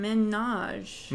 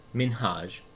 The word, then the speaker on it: Minhaj: She was saying Hasan Minhaj. – Minhaj, like Nicki Minaj. – Yeah. – And his name is Hasan Minhaj.